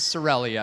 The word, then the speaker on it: Sorelli: I've been twice now to Sorelli, which it is kind of hard to pronounce, I guess, but I put... it is Sorelli, I've confirmed.